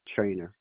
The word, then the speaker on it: Trainer: Dikembe Trainer.